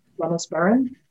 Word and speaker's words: Dennis Baron: It's by Denis Baron, Dennis Baron, I believe is the English pronunciation.